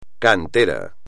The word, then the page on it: cantera: espagnol